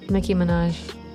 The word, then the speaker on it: Nicki Minaj: Nicki Minaj.